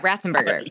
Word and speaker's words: Ratzenberger: Flea, though, which I didn't know, John, I'm sorry if I'm saying this name wrong, Ratzenberger. – Yeah, Ratzenberger.